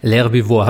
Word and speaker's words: L'Herbivore: L'Herbivore. Habe ich das richtig ausgesprochen? Meines Wissens nach schon, ja.